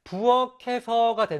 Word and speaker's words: 부엌에서: 부, 밑에 키읔이라는 받침이 있습니다. 그런데 그 뒤에 바로 에라는 모음이 오지요. 이럴 때는요, 받침 소리를 그대로 붙여 발음합니다. 따라서 부엌에서가 되는 거죠.